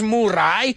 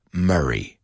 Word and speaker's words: Murray: So Bill Murray, pronúncialo conmigo, que no es Murray. It's Murray. Murray, Bill Murray, and the movie is Groundhog Day.